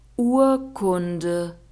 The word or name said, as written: urkunde